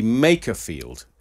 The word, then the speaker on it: Makerfield: And particularly all the people who have texted in to correct me on how I pronounce what I now know to be Makerfield.